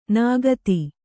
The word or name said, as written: ನಿಖಾತ